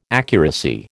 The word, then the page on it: accuracy: American English